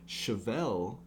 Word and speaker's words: Chevelle: Chevelle, that's how you say it, right? – That's what I would say.